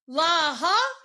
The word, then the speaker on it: La'a: Laha, is that how you pronounce it? – It's La.